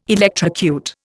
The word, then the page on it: electrocute: Indian